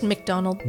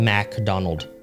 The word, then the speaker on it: McDonald: It's just McDonald. – No, it's Mac, actually. – No, it's pronounced McDonald. – MacDonald.